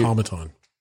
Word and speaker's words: automaton: I think it's automaton. – Yeah, nailed it. Automaton. That makes sense after you say it that way.